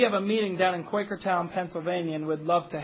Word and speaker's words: Quakertown: It's not convenient to drive 6 hours to Quakertown. Which, by the way, I've been pronouncing Quackertown.